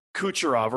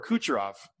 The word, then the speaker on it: Kucherov: I hope Cal Clutterbuck just catches Kucherov or what's his name? Nikita Kukaniki. Who's Tampa Bay's goal scorer? Kucherov. Yeah, you said it right the first time.